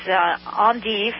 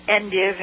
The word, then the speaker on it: endive: The other thing is endive, or endive in U.S. American pronunciation, or the other name for it is escarole, or Royal Batavian endive is another name for it.